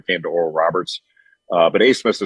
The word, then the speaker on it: Abmas: You remember Oral Roberts beat Ohio State a couple years ago, and they still have the same guard, Max Asmus, spelled A-B-M-A-S, and it's pronounced Asmus.